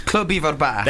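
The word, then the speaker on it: Clwb Ifor Bach: Am I pronouncing it right? Club Ivor Bach. – Club Ivor Bach. – There you go.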